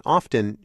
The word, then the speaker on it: often: In fact, he says the T in often should be silent.